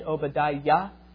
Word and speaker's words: Obadiah: It's an abbreviation of the name Obadiah, most likely. Obadiah being the servant of Yahweh. Do you hear the Yah at the end? Obadiah.